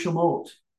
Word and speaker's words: Shemot: In Hebrew, the word Exodus pronounced Shemot.